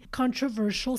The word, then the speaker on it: controversial: Practice the pronunciation of this word with me here. Controversial. Shush. It's almost as if you are telling someone to lower their voice or be quiet, right? Shh, like controversial, controversial.